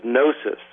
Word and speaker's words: gnosis: They're called Gnostics because of the Greek word for knowledge, which is gnosis, spelled with a G.